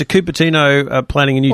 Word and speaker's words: Cupertino: Now, you didn't say that properly. It's Cupertino. – Oh, Cupertino. Oh, yes, it is. – No. – It is Cupertino.